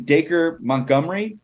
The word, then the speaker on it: Dacre Montgomery: But also, I think this is how you say his name, Dacre Montgomery.